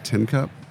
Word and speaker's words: Tincup: Everyone, my last name's Tinkup. And I'm like, you really can't mispronounce Tinkup. You'd be surprised at how many people are teacup. Tin can? No.